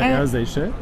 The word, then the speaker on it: Air Seychelles: Ist das Air Seychelles oder Air Seychelles? – Es ist Air Seychelles.